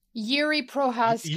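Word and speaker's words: Jiří Procházka: Jiri Prochazka. – Jiri Prochazka, right? – Prochazka.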